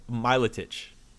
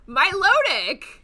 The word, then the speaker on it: Milotic: Oh, number 12, Miletich. No, give me a second. That's Miletich. I'm gonna say Miletich. – Are you what? – Miletich. – No, Milotic.